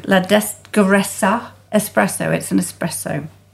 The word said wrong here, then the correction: La Pedregosa: How do I say that? – La Pedregosa. La Pedregosa espresso.